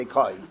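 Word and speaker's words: Koi (כוי): I'll say Kvi and everyone's going to correct me. No, that's not how you say it. You say Koi.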